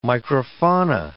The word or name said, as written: microfauna